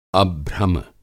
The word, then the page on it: अभ्रम: Hindi